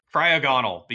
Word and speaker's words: Cryogonal: How did you say D? – I don't know how to say it. – Cryogonal, because Cryogonal. It's like a, he's got the shape. – You flipped your G and your N. – Cryogonal. There we go.